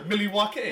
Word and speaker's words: Milwaukee: Say it properly. – Milwaukee. – Yeah, there you go.